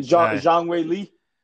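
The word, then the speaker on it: Zhang Weili: Zhang Wei Li.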